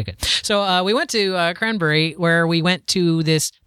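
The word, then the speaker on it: Cranbury: Is it spelled like cranberry, but you say Cranberry? – No, B-U-R-Y, Cranberry, because it's near Danbury and Southbury.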